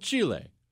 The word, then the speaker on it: Chile: And that is how you say it, Chris. Stop making that face. That's how you say it, okay? I habla a little bit, and it's called Chile.